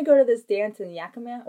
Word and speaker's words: Yakima: For reference, the ball is in Yakima, Washington.